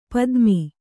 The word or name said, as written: ಪತಮ